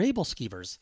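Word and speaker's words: æbleskiver: I think I called it like Abel skivers or something. And my wife says, "No, those are Abel skivers."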